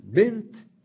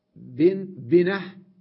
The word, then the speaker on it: بنت: يقول ولأنها لا تبدل هاء في الوقف، لاحظتم كلمة بنت عند الوقف تلفظ تاء بنت، عندما نصل جاءت بنت فلان، إذن تلفظ تاء في الوقف وفي الوصل.